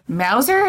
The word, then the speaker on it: Mauser: He had two 22s, or 22 rifles, a 22 pistol, a 7.65 Mauser, is that how you say it? – Yeah.